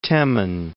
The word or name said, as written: Temin